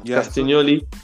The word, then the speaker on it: Castagnoli: Yeah, Castagnoli.